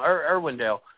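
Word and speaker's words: Irwindale: Irvindale is the name of the racetrack there in Bakersfield.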